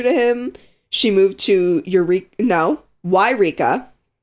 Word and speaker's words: Yreka: but I know that it's Yreka. For those of you listening who are still laughing at Lohea Lajala, I promise you, this is Lajala. How you say it, and it's Yreka.